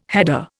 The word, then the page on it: header: Indian